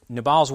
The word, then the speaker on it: Nabal: And Abigail, Nabal's wife, hears about it. Nabal, let's just say Nabal. It's easier to say than the more Hebrew pronunciation.